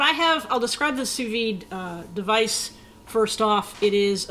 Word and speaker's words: sous vide: It's sous-vide, right? – That's 100% it, sous-vide. We're going to do the, or as the fancy people would say, sous-vide, which is spelled S-O-U-S, second word, V-I-D-E.